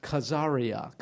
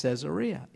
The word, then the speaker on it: Caesarea: If you go to Israel today, you'll hear it pronounced Caesarea, Caesarea. And you'll say, what in the world? That's Caesarea. It's named after Caesar, Augustus Caesar, who was a patron of a fellow named Herod the Great.